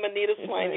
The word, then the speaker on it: Anita Swain: Just type in my name, Anita Swain, you'll find me.